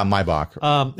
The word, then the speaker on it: Maybach: And so Wide Sky is actually, so the Wide Sky is very similar to the, is it Maybach? How it's pronounced? – Yeah, Maybach.